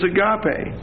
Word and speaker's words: agape: In the Greek is agape. A-G-A-P-E. It's a long E.